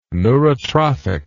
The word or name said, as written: neurotrophic